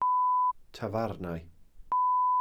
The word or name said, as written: tafarnau